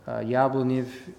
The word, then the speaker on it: Yablonev: Yablonev means apple, and so we call this one Apple Village, but you pronounce it Yablonev.